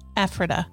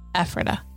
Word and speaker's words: Ephrata: It's pronounced Ephrata in Lancaster County. Ephrata.